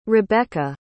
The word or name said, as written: rebekka